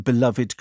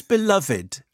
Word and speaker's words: beloved: Easily Britain's most recognisable and beloved critic. Why is beloved, why is it three syllables? – Why aren't you just beloved? – Well, actually, you can say beloved.